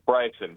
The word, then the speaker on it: Bryson: Bryson, how do we say it? – Thank you. Bryson. – Bryson. Yes, mea culpa. – It's a weird story. – It was. I was going with the Latin pronunciation there. – No, it's Irish.